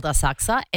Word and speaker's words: Asakusa: There's a suburb in Tokyo called Asakusa, and it's spelled Asakusa. So phonetically, Asakusa. But yeah, you don't pronounce the U, and because I'm basically a local, I know that it's called Asakusa.